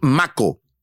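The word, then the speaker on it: mako: Quedó varado el tiburón, un tiburón maco. Así se pronuncia, así es. M-A-K de kilo.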